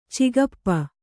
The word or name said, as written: ಚಿಗಪ್ಪ